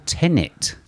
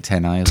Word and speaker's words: Tenet: A rushed together clip for Tenet. Been saying it wrong for But you've been calling it 10A as well.